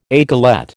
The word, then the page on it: aiguillette: American English